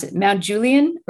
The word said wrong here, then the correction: Gulian: or? – Gulian. – Gulian. Okay.